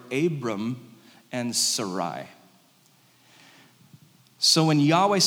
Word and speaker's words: Yahweh: God's name is four Hebrew letters, often pronounced Yahweh. And that hey, it's a hey in the Hebrew. It has a breath behind it. It's very intentionally spoken with a breath, Yahweh.